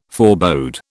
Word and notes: forebode: British English

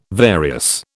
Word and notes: various: British English

